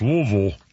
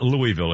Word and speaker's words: Louisville: And in Louisville, I'm sorry, that's pronounced Louisville. For the record, it's not Louisville. If you ever say Louisville in Kentucky, they'll throw something at you.